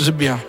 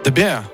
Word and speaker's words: The Bear: The Beer, l'ours, ouais. – Alors justement, il y a Aurélien qui nous dit que c'est peut-être. Euh, ça se prononce pas comme ça, en fait. The Bear. The Bear.